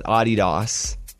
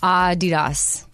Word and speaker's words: Adidas: Okay, as Americans, we're pronouncing Adidas wrong. And I wear Adidas all the time. – Yeah, so I have an Australian friend, and she would call it Adidas.